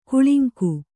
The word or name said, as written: ಕಳಂಕ